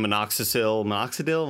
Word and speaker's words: minoxidil: But I think a lot of it was just a lot doing minoxidil.